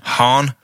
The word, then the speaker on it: Hahn: Is that how you say it? – Hahn. – Hahn. Catherine Hahn. – As in a posh person saying Han Solo.